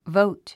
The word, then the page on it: vote: vóut; ボォウト